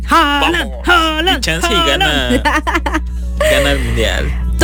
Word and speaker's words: Haaland: Holland. Cuando lleva dos A, se pronuncia O. – Holland.